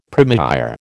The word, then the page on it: première: American English